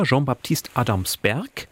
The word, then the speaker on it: Jean-Baptiste Adamsberg: Figur, Jean-Baptiste Adamsberg, extra französisch ausgesprochen, man könnte auch sagen Johannes Adamsberg.